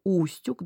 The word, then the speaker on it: Устюг: Устюг, да, ключевое слово, потому что все, кто были в этом городе, были на экскурсии, они знают, почему устюг и почему ударение на первое слово.